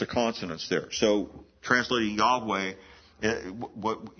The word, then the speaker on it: Yahweh: It's interesting, in the Hebrew, we sometimes translate some people would insert the name Jehovah, but Jehovah, there's no J sound in the Hebrew alphabet. the closest we would come to understanding how that name is pronounced is Yahweh. Y-A-H-W-E-H.